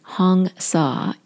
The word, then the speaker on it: Hong Sa: And as I mentioned, I've shared this before with you, and the word Hong Sa, it's Hong, H-O-N-G, just like Hong Kong, and Sa is spelled S-A-U. It's pronounced like S-A-W, like the English word Sa.